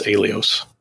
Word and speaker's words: Aiolos: I just pronounced it Aiolos or Aiolos. See, I can't pronounce it when I'm looking at it. – I think it's Aiolos. – A-I-O-L-O-S. Aiolos.